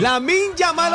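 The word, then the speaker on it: Lamine Yamal: ¡Lamin Yamal, hombre! ¡Lamin Yamal! – ¿Vos sabéis que en español se pronuncia como, Se lee? – Como se lee y se escribe.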